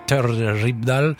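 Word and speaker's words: Terje Rypdal: Oui, et il lui faut la guitare un peu rocailleuse de Terje Rybdal, prononcée inconfortablement, très bien, parfait.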